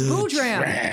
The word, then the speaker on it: Boodram: It's just Boodram. It's phonetically correct. – Boodram.